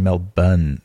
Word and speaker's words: Melbourne: They spell it Melbourne, but you're supposed to say Melbourne.